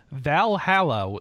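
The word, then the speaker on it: VA-11 Hall-A: Top three Vita games, Valhalla, the virtual bartender simulator.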